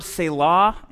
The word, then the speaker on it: Selah: Last thing I'll say about the Psalms is, you know this word Selah in English? It's actually that pronounced the same way in Hebrew as well,